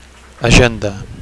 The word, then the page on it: agenda: Catalan